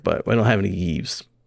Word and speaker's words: Yves: Is it Yves? How do you say it? – It's a Yves Yves? – Guillemot.